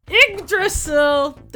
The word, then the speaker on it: Yggdrasil: Oh, I didn't even tell you how to pronounce the Yggdrasil.